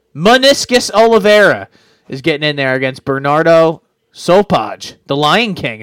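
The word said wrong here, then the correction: Sopaj: Is that how you say his name? Is that turned into an I? I don't know. I never know. Stockholm, yes. Sweden. Sopai? – Yeah, Sopai, yeah, sorry.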